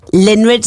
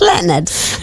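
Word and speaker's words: Lynyrd: it's written like Linrid Skinrid. And he says it's Leonard. I'm like, I'm sorry if it's Leonard. It should be spelt as L-E-O-N-A-R-D. And on the system, it's spelt as L-Y-N. – Yeah. – Y-R-D, that's Linrid, and I don't know a Linrid, so I'm like, I'm not saying the word Linrid on air.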